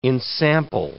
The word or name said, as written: ensample